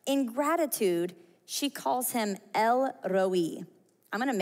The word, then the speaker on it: El Roi: If you can roll your R's, really go for it here. But El Roi, say it one more time, El Roi. I said this wrong for the last million years. I thought it was like El Roi.